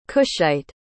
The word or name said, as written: cushite